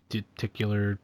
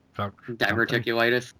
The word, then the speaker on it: Diverticulitis: Diverticular. – Diverticulitis.